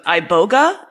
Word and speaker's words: iboga: You've talked about using one dose of iboga. Am I saying that right? Mm-hmm. Okay, iboga, to treat drug addiction, like heroin and meth addiction.